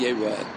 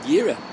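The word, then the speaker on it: Yeroa (יֵרָאֶה): We have a tradition that the Kri, the way he's supposed to pronounce it, is Yeroa, but the Ksiv could be read Yira. So that's also considered a Kri Ksiv. Kri Ksiv is not only when there's a contradiction between the way it's spelled according to the Kri. The way it's spelled according to the Ksiv, even if it's the same spelling. But the tradition has it that the correct way to read it is Yeroeh, but you could have read it otherwise, so that's also a Kri Ksiv.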